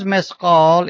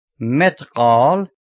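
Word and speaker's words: Mesqal (مثقال): Because Mesqal is transliterated with TH, by mistake it may be pronounced Metqal, which is a certain kind of cloth.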